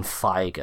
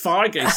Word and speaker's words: Feige: That Kevin Feige, I think it is. – Feige. I was told it was Feige because I kept saying Feige. Right. And I was like, it's not Feige, it's Feige.